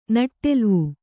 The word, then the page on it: ನೆಟ್ಟೆಲುವು: neṭṭeluvu